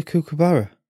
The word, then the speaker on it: kookaburra: The fuck's a kookaburra? – A bird. – Kookaburra. – Kookaburra, I believe it's pronounced, yes.